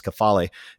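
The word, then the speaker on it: kephale (κεφαλή): And this idea of the word kephale, that's the Greek word, its pronunciation is kephale.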